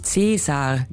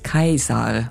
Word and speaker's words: Caesar: Gaius Julius hätte sich allerdings gar nicht angesprochen gefühlt, wenn man ihn Caesar gerufen hätte. Denn sein Name wurde damals als Kaiser ausgesprochen.